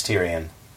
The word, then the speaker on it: Tyrion: Because I thought it was Tyrion. – No, it's Tyrion.